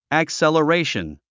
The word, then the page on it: acceleration: əksèləréiʃən; アクセラレィション